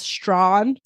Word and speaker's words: Strachan: I don't know if I'm pronouncing that correctly. That's how the Wikipedia page told me to pronounce it, but it doesn't look like that's how it's pronounced. It's Strachan. Apparently, that's Strawn somehow.